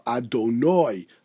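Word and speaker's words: Adonoi (אֲדֹנָי): Ashkenazim should certainly pronounce the way, as I said it, Baruch ato Adonoi lamdeni chukecha, and not I, as Svardim pronounce it.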